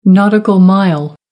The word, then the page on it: nautical mile: American English